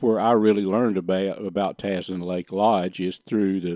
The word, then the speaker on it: Tazin: But Tazin Lake Lodge, T-A-Z-I-N, that's the way it's spelled.